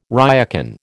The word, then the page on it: ryokan: American English